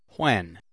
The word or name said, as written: when